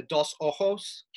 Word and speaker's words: Dos Ojos: I believe they are the Sa- Sac Actun and the Dos Ojos caves, if I'm pronouncing that correctly.